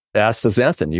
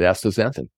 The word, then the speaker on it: Astaxanthin: And really this particular anti-inflammatory, which again, that's kind of the segue into the next question here, and the Astaxanthin. I hope I pronounced that correctly. – Astaxanthin. You got it. Astaxanthin.